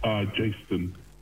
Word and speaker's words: Jaistone: Jaistone. – Jaistone. – So like Jason and Justin together.